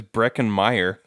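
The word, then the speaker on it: Breckin Meyer: And then you said Spencer played by Brecklyn Mayer. His name is Brecken Meyer.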